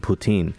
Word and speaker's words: Putin: It's not Putin, it's Putin is actually like the Canadian dish is actually the correct pronunciation of his name, Putin.